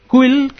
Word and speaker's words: Cuil: Quill, que se pronuncia como cool, como guay, ¿no? Así en inglés, cool, se escribe C-U-I-L.